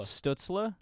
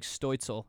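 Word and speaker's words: Stützle: how do you say his name? – Stutzla. – Stutzla? – That's how I say it. – Oh. Yeah, Stutzla. – I thought it was like Stoitzel or something German sounding.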